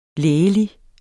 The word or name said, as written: lægelig